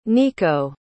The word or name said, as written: niko